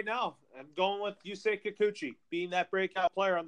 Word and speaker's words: Yusei Kikuchi: Yusei Kikuchi.